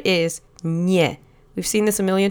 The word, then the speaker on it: nie: It's one syllable. Let's say it together three times. Nie. Nie. Nie.